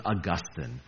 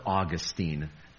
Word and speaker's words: Augustine: His name was Augustine, or Augustine. Both pronunciations are correct.